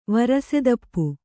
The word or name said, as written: ವರಸೆದಪ್ಪು